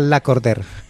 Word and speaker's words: Lacordaire: Es que es un nombre francés. Se escribe Lacordaire, pero se pronuncia Lacordaire.